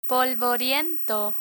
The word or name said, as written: polvoriento